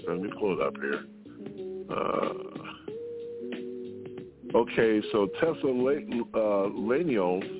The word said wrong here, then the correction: Thessalonians: I guess I said that wrong, 1:4-18 says, but we do not warn you to... No, I don't think that's it, Ms. Elias. You said that wrong, too, so get yourself straight. – Okay. – All right, Thessalonians. That's right.